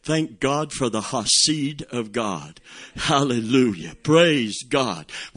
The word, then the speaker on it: Hesed: H-E-S-E-D. That's how it's spelled. H-E-S-E-D. But it's pronounced Hasid.